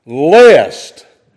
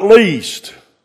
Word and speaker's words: lest: L-E-S is pronounced lest, not least. Those are two different words.